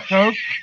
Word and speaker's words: Koch: The Koch Brothers? Koch? How do you say them? – Just like that.